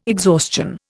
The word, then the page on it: exhaustion: Indian